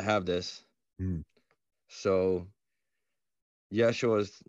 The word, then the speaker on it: Yeshua: we have that sh sound that's lovely so we can we can say yeshua the greek didn't have that so so jesus was was about as good as they could they could do for Yeshua.